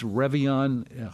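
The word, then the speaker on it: Réveillon: In case you are not aware or have never quite understood, what is this Revillon? How do you even spell that? It's a French word, R-E-V-E-I-L-L-O-N. Revillon, it means an awakening.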